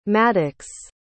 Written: Maddox

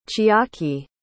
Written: chiaki

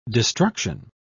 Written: destruction

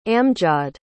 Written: amjaad

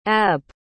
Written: abb

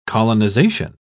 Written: colonization

